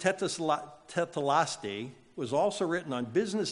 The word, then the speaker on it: tetelestai: In the original Greek, the term is this word, tetelaste. Tetelaste. It is spelled this way if you want to write it down. T-E-T-E-L-E-S-T-A-I. Tetelaste, which literally translated the term, it means it is finished.